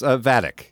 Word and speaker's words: Vadic: Vadic is her name. – Yeah, we were saying it wrong earlier.